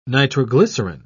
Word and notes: nitroglycerin: British English

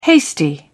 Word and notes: hasty: British English

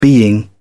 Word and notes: being: British English

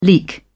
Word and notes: leek: British English

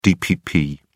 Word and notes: dpp: British English

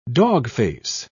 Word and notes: dogface: British English

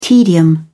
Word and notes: tedium: British English

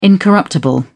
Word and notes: incorruptible: British English

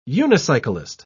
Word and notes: unicyclist: British English

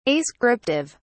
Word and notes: ascriptive: British English

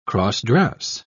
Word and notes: cross-dress: British English

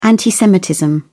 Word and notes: anti-semitism: British English

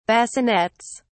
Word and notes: bassinets: British English

